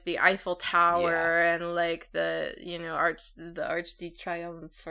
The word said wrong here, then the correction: Arc de Triomphe: or I'm saying it wrong. – Arc de Triomphe.